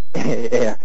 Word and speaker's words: Ayer: Ayer. It's A-Y-E-R.